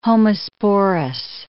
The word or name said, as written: homosporous